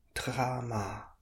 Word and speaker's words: trama: So that's got two long A's. Tra and ma, trama.